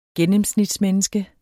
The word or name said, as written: gennemsnitsmenneske